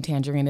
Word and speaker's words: Tanjareen: I will text you you can find me on all social media platforms if you know that the name Tanjareen is not spelled like the fruit.